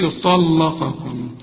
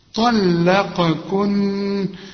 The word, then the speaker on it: طلقكن: عسى ربه إن طلقكن، أهدي عايزة بقى إيه فن بقى في النطق، ما تقولش طلقكن، طلا، ما أنت عارف اللام مرققة، طلا، قاكن، وخد بالك في قاف وفي كاف، ادي كل حرف حقه، طلقكن،